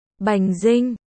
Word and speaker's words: Dinh: Bành Vinh. Đoàn Chí Viễn hỏi lại. Bành Dinh. Không phải là do tôi phát âm sai đâu.